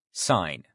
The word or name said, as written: sign